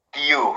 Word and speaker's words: Guillou: Guillou, who wrote Hunskan and a lot of, like, I love his book.